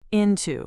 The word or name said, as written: into